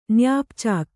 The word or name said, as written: ನ್ಯಾಪ್‍ಚಾಕ್